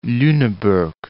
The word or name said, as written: Luneburg